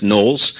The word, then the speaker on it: Knollys: It says Knollys, but his name is actually pronounced Knowles.